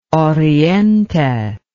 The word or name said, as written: oriente